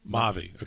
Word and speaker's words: Mavi: Mavi. Okay, Mavi is the parent company to 34 Heritage. – I didn't know that. – So Mavi means blue in Turkey.